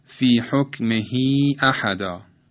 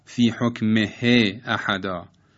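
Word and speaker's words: في حكمه أحدا: در این دو مثال غلط است که تلفظ کنیم فی حکم ه احدا یا مندونه ه ملتحدا، بلکه هم تو عرض کردم به خاطر متحرک بودن قبل و بعد هاء ضمیر حرکتش اشباع میشه.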